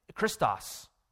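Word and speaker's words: Christos (Χριστός): I know it's in Greek, but Christos, that's how that word is pronounced.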